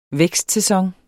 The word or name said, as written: vækstsæson